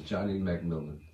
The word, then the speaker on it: Johnnie McMillon: My name is Johnnie McMillon.